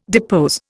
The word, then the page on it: depose: Indian